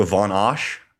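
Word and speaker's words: Van Assche: And we have another French champion in Luca Van Asch, or John, would you like to pronounce his name properly for me? – Luca Von. – Oh, I'm so sorry. Luca Von Asch? Asha? – Yeah, there you go.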